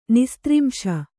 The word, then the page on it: ನಿಸ್ತ್ರಿಂಶ: nistrimśa